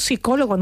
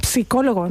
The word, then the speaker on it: psicólogo: La palabra psicólogo se escribe con una P inicial, pero esa P no la pronunciamos. – Es verdad, es verdad. – Decimos psicólogo, no decimos psicólogo.